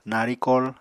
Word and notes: narikol: Apatani

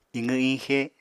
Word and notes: iqw-iñxe: apatani

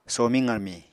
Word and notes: somiñ qarmiñ: Apatani

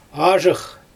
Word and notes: azhyx: Xyzyl

